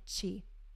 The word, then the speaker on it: qi: So in TCM, stuck emotion is viewed as stagnant qi, which is spelled Q-I.